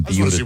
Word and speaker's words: Buda: No, that's how it's pronounced. – Okay. – Well, if, is Jason, it B-U-D-A? – Can you get someone on the phone from Beulah, Texas, please? – You know, Josh B-U-D-A.